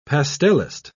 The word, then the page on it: pastellist: British English